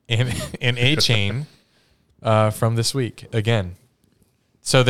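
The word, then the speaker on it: Achane: It's A-Chain. That's how everyone's been saying it for years, but it came up. – Years? How old is he? – He's a rookie. – Oh. Where do you go to college? – Mississippi State. – Okay. – But he came out this week and said A-Chan is actually how it's pronounced. – A-Chan, like 4chan.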